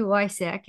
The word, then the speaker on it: Woycik: I'm your hostess, and today I get to hang out with Sherri Lee Woycik, and I should have asked her how to pronounce her last name.